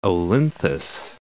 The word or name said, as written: Olynthus